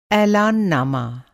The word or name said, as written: ऐलाननामा